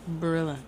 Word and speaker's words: Barilla: Is it pronounced Barilla or Barilla? – Barilla. – Barilla? – Mm-hmm. – Like Gorilla? – Mm-hmm.